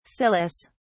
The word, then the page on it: cilice: British English